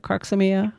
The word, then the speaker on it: Crocosmia: Another one that I have just fallen in love with, and it's pretty inexpensive, that Croxomia, did I say that right? – Crocosmia.